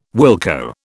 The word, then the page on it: wilco: British English